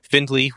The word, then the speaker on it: Findlay: Findlay with a D and an A.